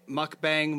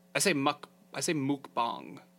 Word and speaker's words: mukbang: Mukbang, mukbang, mukbang. I say mukbang.